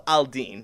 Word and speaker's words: Aladdin: So, our main character, this is a 1001 Arabian Nights story, is Aladdin, or he's called Al-Din. That's just a different way to pronounce it there.